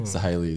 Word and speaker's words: Sahely: So Sahely is the correct pronunciation, but well done.